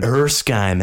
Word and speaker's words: Erskine: But everything changes when Dr. Erskine, how do you say that? – Erskine, there we go.